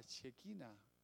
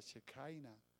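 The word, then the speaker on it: Shekinah: Es lo que llaman la Shekina, o otros lo pronuncian en inglés, la Shekaina de Dios, que no es otra cosa que la gloria de Dios, lo luminoso, la gloria de Dios es esa luz.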